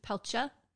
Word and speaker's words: Peltier: It's spelled P-E-L-T-I-E-R, but when I looked it up, that's how it was pronounced by a news, Peltier.